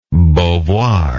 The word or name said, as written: beauvoir